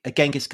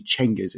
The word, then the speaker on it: Genghis: So Genghis Khan, not the correct pronunciation, right? – It isn't actually. Well, as far as we can tell, because actually the more accurate pronunciation or rendition seems to be, and I dare say among Persian historians of the Mongol period, and it's actually Genghis. It's a CH rather than a G.